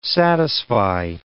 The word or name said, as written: satisfy